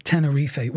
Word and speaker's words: Tenerife: He is calling it Tenerife, which is the correct pronunciation.